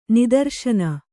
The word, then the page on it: ನಿದರ್ಶನ: nidarśana